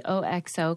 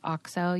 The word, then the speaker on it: OXO: I read an article about you might call it the OXO company, but it's actually pronounced OXO.